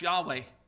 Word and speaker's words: Yahweh: That Jehovah name, that's an anglicized word for a composite of some Hebrew, but the actual name is perhaps pronounced Yahweh.